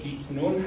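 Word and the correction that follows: Guignon: دوباره که بعد از چاپ کتاب نگاه کردم دیدم گینیون، یعنی این یه جوری تلفظ میادم، هم گش میشنوی هم نه، ولی گینیون،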